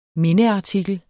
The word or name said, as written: MINDEARTIKLER